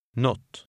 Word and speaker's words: något: In daily conversation, this is often pronounced not.